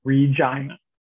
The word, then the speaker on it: Regina: It is the city that rhymes with fun. – Yes, it's called Regina, not Regina, but Regina. And it should be, but that's not what it's called.